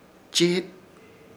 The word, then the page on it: Chiit: Ishir Chamacoco